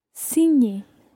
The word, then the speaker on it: signer: Signer. – That's spelled S-I-G-N-E-R.